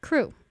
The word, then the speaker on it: CHRU: WXYC would like you to know about CHRU, also known as the Carolina Harm Reduction Union, pronounced CREW.